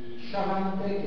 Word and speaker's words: Xavante: Sciamante, che si scrive con la X, Xavante, ma si pronuncia Sciamante,